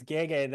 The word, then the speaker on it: Gege: I think it's Gege. That was the only one. – Oh, Gege. Okay. Yeah, you know, I just read the pronouncer they give me. So that's right.